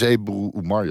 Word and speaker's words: Ebru Umar: Nou, dit is Ebru Umar, Jan. – Ja, je spreekt mijn naam goed uit, Jan.